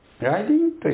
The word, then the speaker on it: 来臨: 来臨というのは来るという字と望むという字を書いて来臨と読みます。